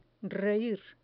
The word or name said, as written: reir